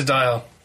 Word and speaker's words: Dier: Oh, Dier, I think it's pronounced. We need to sort out this name. – We're committing to Dier. – We are, because that's what lots of people are saying it is.